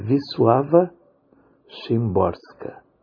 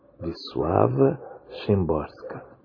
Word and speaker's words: Wisława Szymborska: Mas a pronúncia do nome dela é aproximadamente, ok? Wisława Szymborska. Wisława Szymborska.